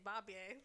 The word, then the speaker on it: Bobye: which I would like to point out is spelled B-O-B-Y-E, so I say Bobby.